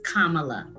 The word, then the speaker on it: Kamala: And for continuing the right-wing racist pronunciation of her name. Since you like to educate us, let me educate you that her name is Kamala.